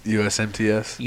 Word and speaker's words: USMTS: You get the USMST. Well, I butchered that, didn't I? – USMTS. – USMTS. I got those letters completely twisted up.